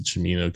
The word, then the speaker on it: Cimino: The notorious box office bomb comes from Marxist filmmaker Michael Cimino or Camino. I pronounce it Cimino because it's Italian, but you know, whatever.